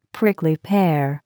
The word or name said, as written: prickly pear